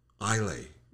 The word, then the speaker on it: Islay: And it is, I hope I'm saying this correctly, Islay. – The S is silent. – Islay.